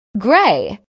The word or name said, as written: gray